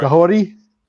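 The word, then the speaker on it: Kahhori: Cahorty? Did I say it right? – Cahorty? – Yeah. Cahorty. Cahorty? – Oh, okay.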